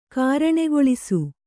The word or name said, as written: ಕಾರಣೆಗೊಳಿಸು